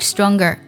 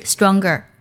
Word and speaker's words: stronger: 我们需要把词尾的 g 读作 g 的音，然后加 er，读作 stronger，stronger。 You are so much stronger。首先呢，我们的 much 来修饰 stronger，强壮太多。